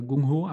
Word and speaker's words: Gung Ho: Ähm, das heißt eben auch Gang Ho oder Gung Ho, aber ich würde es auch Gang Ho aussprechen.